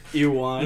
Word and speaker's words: Ewan: Why do you say it like that? – What, say it like what? – Ewan McGregor? – Yeah. – That's how you say it.